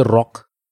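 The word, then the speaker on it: Rukh: इसको इंग्लिश में कई बार आर ओ सी भी लिखते रॉक but I think it would be pronounced as रुख आर यू के एच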